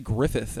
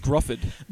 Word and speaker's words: Gruffudd: No, I've heard people say Griffith, and I'm like, there's none of that. – Griffith? – It's Gruffudd.